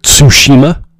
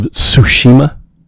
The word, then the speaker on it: Tsushima: By the way, the new game, Ghosts of I'm going to butcher it, Tsushima. It's a T and an S together in the start. Tsu, Ghost of Tsushima.